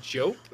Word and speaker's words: Diop: I'm going to miss Matar Diop, I think is how you say it. – Matar, I think it's actually Jope. Like Hope with a J is what the pronunciation guide says.